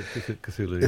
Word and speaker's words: Cthulhu: Lovecraft guy, but Cthulhu or Tulu, Cthulhu, yeah.